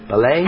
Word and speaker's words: Ballet: Did I say that right, Laura? Ballet? – Hello, Ben. Yes, hi. It is Laura Brennan Ballet.